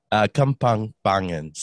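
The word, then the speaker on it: Kapampangan: So tongue twister for you guys, it's Kampampampangan, or I didn't even say it properly, but yeah, it's within those guidelines. – Well, can you say it again? – Kampampangan.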